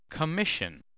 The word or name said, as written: commission